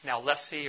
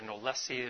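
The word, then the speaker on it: knowlesi: Malariae and then Nalesi or Nolesi, there's a couple different ways to pronounce it, seen in Borneo, which is kind of a hybrid of Vivax and falciparum in the way that it presents.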